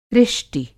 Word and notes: ऋष्टि: Hindi

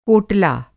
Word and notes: कोटला: Hindi

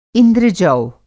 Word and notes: इंद्र-जौ: इंद्रजौ; indrajau

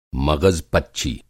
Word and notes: मग़ज़-पच्ची: Hindi

